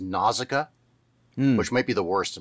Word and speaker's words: Nausicaa: But there was a song released with the movie that like broke it down by syllable. Nausicaa. So I'll just choose that.